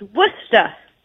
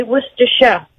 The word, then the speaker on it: Worcestershire: Oh, so, because you're from England, you know how to say Worcestershire. – Yes. – Did I say it right? – Almost. Worcester. – Worcestershire. – Yeah. Worcestershire. – No, you're wrong, Sherry. Worcestershire. – You don't have to say Shire. You just say Worcestershire.